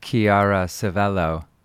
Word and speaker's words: Chiara Civello: You just heard from CCCC, which is, if my Italian pronunciation is correct, Chiara Civello, and producer, whose name is Cristiano Cristi, actually, but he goes by Clap Clap.